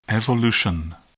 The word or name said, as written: evolution